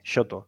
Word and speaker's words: shoto: Uno lo llaman un shoto, un soto, SH, shoto, ¿no? Sería así como se pronuncia.